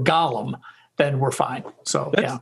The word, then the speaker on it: Gollum: And in Lord of the Rings, it's Gollum, spelled differently, pronounced differently.